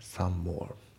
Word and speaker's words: some more: で、あと発音ですけど、 some more のね、 some の M が抜けますね。 some more Some more じゃなくて some more ですね。